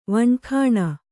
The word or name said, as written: ವಣಖಾಣ